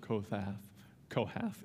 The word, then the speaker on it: Kohath: as the camp sets out after the sons of Kohath is how you say that, shall come to carry these, but they must not touch the holy things, lest they die.